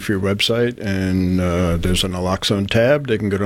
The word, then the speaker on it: naloxone: I know other things within your programs is giving out or distributing naloxone, am I saying that correctly? Yes.